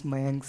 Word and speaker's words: Mayank: And hi, my name is Mayank Singh, and you can pronounce my name like, just say my uncle without saying the L part.